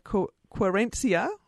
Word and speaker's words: Querencia: Steve's got a high opinion of Querencia. I hope I've pronounced that right. – Yes, that's right.